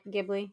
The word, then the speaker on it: Ghibli: Is that how I say it, Ghibli? – Yes.